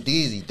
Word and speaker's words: Deexy: Type in Dizzy. DEEXY. That's how you spell my name. But it's pronounced Deezy.